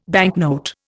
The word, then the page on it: banknote: Indian